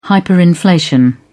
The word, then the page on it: hyperinflation: British English